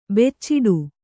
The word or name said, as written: ಬುಚಡೆ